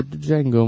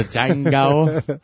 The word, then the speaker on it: Django: The D is silent, nigga. – The Django.